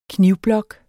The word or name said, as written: knivblok